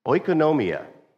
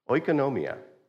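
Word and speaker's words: Oikonomia (οἰκονομία): Oikonomia. Oikonomia.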